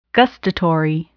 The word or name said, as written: gustatory